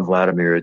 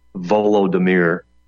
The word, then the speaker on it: Volodymyr: By the way, you mispronounced Zelensky's name. It's not Vladimir. It's Volodymyr or Voldemort.